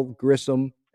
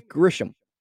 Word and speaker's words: Grisham: And he was like, oh, how big is – he said something like, how big are the home runs from Bell, Grissom? And it's like, his name is Grisham. Who is Grissom? – It's not that hard to pronounce, like, Grish. It's kind of self-explanatory.